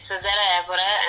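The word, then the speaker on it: Cesária Évora: And there's another one called, now forgive me if I pronounce this wrong, yeah? Cesaria Evora. And the song's called Soldade or Soldad. – Yeah, it's Cesaria Evora and the song is called Soldad.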